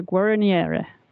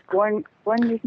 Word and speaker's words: Guérinière: Was it François Boucher or was it François Robichon de la Guérinière? – It was Guérinière.